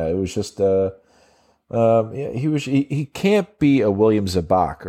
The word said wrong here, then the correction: Zabka: Zabka or whatever.